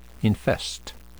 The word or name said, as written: infest